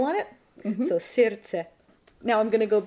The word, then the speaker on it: Сердце: the word for heart, there's actually sounds that we don't pronounce. – Сердце. – Genau, das D, was geschrieben ist, wird nicht ausgesprochen. Definitiv nicht. Exactly. So the D in the middle of that word is just completely ignored.